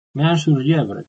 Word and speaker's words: Meung-sur-Yèvre: Meung-sur-Yèvre, se pronuncia así.